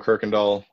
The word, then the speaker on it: Kuykendall: LinkedIn, Joe Kirkendall.